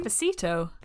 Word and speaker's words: Despacito: Despacito. – I've not listened to it, I don't know if I'm pronouncing it correctly. Despacito. – That there we go.